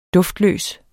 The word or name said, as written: duft